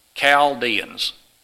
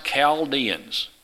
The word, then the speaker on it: Chaldeans: Chaldeans is the way. The H is silent.